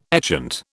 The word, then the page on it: etchant: British English